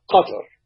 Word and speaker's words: Qatar: Well, Murray, the way to pronounce it in classical Arabic is Qatar, but not many of us speak classical Arabic. So if you put the emphasis on the first syllable, whether it's Qatar or Qatar or Qatar, you can't go very wrong. If you put the emphasis on the second syllable, you're marked immediately for a stranger.